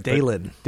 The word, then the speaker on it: Dailen: Dalen. – Dalen is D-A-I-L-E-N.